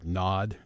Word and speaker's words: Gnod: And before that, we had the brand new one from the UK group Nod, and that is spelled with a silent G, so G-N-O-D.